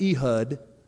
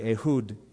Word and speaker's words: Ehud: Now I know it looks like Ehud, but the pronunciation, if you're interested, would be Ehud, the son of Gera, the Benjamite, a left-handed man, a southpaw.